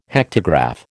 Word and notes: hectograph: American English

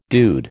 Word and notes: dude: American English

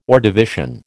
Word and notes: Ordovician: American English

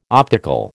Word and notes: optical: American English

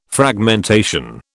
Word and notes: Fragmentation: British English

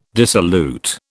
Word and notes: dissolute: British English

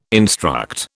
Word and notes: instruct: British English

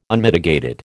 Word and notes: Unmitigated: American English

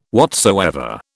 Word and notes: whatsoever: British English

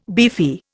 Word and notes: beefy: Indian

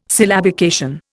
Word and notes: syllabication: Indian

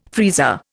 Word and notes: freezer: Indian

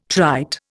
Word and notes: trite: Indian

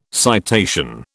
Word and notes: citation: British English

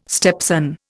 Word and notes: stepson: Indian

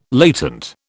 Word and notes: latent: British English

